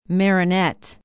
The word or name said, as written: Marinette